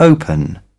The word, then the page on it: open: British English